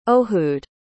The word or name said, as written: ohoud